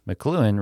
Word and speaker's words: McLuhan: McLuhan